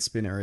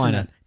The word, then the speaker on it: Spiner: And it's Spino. If we're getting a, pronunciation, it's Spino. – It's definitely not. That's Spinner. – No, it's not. The two N's is Spinner. – Yeah, that's how you spell it. Let's look it up. Let's Brent Spiner. Data from Star Trek. – Yeah, I know. – It's Spiner. One N.